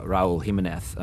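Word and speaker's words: Raúl Jiménez: One, I guess, who has shown his capacity to play at the highest leagues across the world is striker Raul Jimenez.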